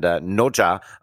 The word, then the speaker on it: Nezha: And Noja, very happy because it's spelled N-E-Z-H-A.